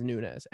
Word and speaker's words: Nunes: My last name is Nunes. N-U-N-E-S.